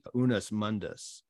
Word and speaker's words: unus mundus: But he also argued for, and I'll probably slaughter the pronunciation of this, I think it was the unus mundus. – No, that's right.